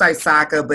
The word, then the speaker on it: ISACA: It's pronounced ISACA, but it's the Professional Organization for Auditors and Information Security Professionals.